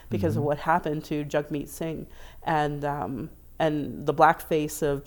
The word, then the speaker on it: Jagmeet: I found out that it's Jagmeet Singh, but I was saying Jagmeet Singh because that's how it phonetically looks. But if you go onto his Twitter account, it says Jagmeet like hug meat.